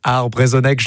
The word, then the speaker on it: Arbrezonek: Gwelbroadel, Arbrezonek, je l'ai bien prononcé ? – Parfaitement.